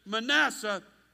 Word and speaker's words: Manasseh: And to preserve his good name, they pronounce it Manasseh instead.